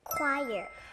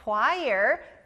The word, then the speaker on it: choir: 크 소리가 나는 그 다음 단어는 여러 사람들이 모여서 노래를 부르는 합창단. 영어로 뭐였죠? – Choir. – 아주 잘했어요. Choir. 여기 크 소리 들렸죠?